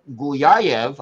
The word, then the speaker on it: Guliaev: So our friend Chris Maselli of Locked On Avalanche, continuing with another defenseman here, Russian Mikhail Guliaev. I hope I came close to pronouncing that right. – You got that right.